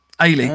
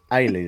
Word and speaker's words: Ailey: I'm a girl and it's pronounced like Haley without the H. Ailey. Oh. There we go. Thank you. Making a note. – Ailey. Ailey, then.